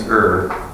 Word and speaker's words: err: Where do some parents err And yes, that is a correct pronunciation of that word.